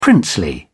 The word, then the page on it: princely: British English